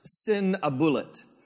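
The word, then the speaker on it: Sîn-uballiṭ: His name in the ancient Akkadian language actually is pronounced Sinabulat.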